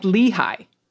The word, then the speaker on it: Lehigh: I'm from the eastern part of PA, and we pronounce Lehigh like Lehigh. Okay. So it's Lehigh, not Lehigh, like I said.